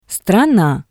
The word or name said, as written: strana